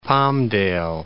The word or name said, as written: Palmdale